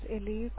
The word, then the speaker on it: Ellie: Hello, this is Ellie calling from provider office.